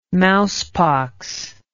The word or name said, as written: mousepox